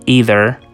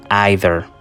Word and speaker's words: either: Y por otro lado tenemos tampoco, que es una sola palabra pero dos posibles pronunciaciones. La primera es either y la segunda either.